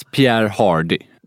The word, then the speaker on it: Pierre Hardy: Alltså Det stavas Pierre Hardy. – Exakt, det stavas Pierre Hardy. – Men det uttalas eftersom det är någon fransman.